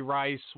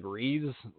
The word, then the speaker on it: Reis: And I could swear he was named like Cody Rice, and it was spelled like Rice, like actual Rice, like Jerry Rice. And now it's like Reese, like R-E-I-S or some shit.